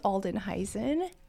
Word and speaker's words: Aldenhuysen: It is Aldenhuysen. It is Dutch. It means old house.